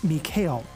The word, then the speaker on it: Mikael: Now, Michael was born Mikael. The name just sort of took on the English pronunciation over time.